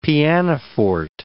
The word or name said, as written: pianoforte